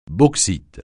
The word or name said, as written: bauxite